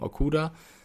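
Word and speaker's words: Okudah: Oder Okuda, wie ich gelernt habe, wird da ausgesprochen, Okuda.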